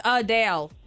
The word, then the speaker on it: Adele: Number one, Adele says we've been pronouncing her name wrong this whole time. It's Adele, not Adele.